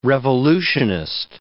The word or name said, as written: revolutionist